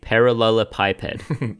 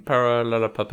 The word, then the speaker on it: parallelepiped: I feel like you said that wrong still. One more time. – It's a parallelepiped. – Parallelepiped. – Okay. That is correct, motherfucker, because I copied the phonetic Google and it's in front of me.